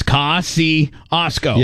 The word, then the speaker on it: Kosciuszko: You spelled it K-A-I. – K-A-S. – No. – The next three words. – Yes, Kosciuszko. – Yeah. – So you spelled it... The first part, K-A-S.